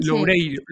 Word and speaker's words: Loureiro: Manuel Lorelo, si no me equivoco la pronunciación, creo que la he dicho mal. Loureiro, creo que sí, sí, sí.